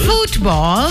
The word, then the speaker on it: football: Football. – Football. Emphasis on the ooh.